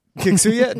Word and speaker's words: Kiksuya: We are discussing Kiksuya? I don't know how to pronounce that. – Yeah, I think that sounds right. – Kiksuya, directed by Uta Brisewitz, who's done Westworld, Jessica Jones, Altered Carmen, This Is Us, Orange Is the New Black, Weeds, and Hung.